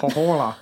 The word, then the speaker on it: Pohjola: I'm going to say Pohjola. That makes most sense. Silent J, right? – Yeah.